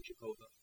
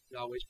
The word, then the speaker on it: Yahweh: How do we say it? Yahweh or Jehovah? Yahweh's probably closer.